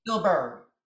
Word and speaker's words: Spielberg: Spielberg.